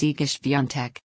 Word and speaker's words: Iga Świątek: With 265,596 views on Saturday, June 8, 2024, our article of the day is Iga Świątek.